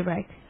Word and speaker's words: Reich: Well, you can pronounce it anyway, but I just say Reich. – Okay. – Like bike.